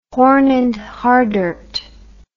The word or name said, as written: Horn and Hardart